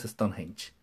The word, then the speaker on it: Stonehenge: Stonehenge. Stonehenge. Bien pronunciado es Stonehenge.